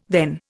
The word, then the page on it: then: Indian